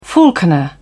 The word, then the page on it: falconer: British English